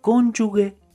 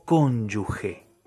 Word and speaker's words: cónyuge: Se dice cónyuge o cónyuge.